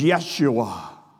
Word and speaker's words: Yeshua: And I think Laura Beth might have hit on this in Bible study Wednesday night, that the Jewish name for Jesus is Yeshua. That's how it's actually pronounced in the Jewish language.